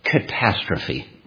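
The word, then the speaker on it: catastrophe (καταστροφή): A little difficult to pronounce. It's pronounced catastrophe. You can see where we get our English word.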